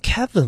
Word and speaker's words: Kevin: 不是，我这个英文名字发音有这么难？Kevin 文超啊。